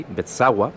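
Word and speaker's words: Beczała: Por su parte, el tenor polaco Piotr Bezawa, que la pronunciación de esa L en polaco es más o menos así, Bezawa,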